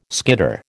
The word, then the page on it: skitter: American English